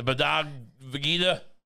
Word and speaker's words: Bhagavad Gita: The Bhagavad Gita.